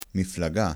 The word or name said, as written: miflaga